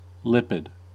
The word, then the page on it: lipid: American English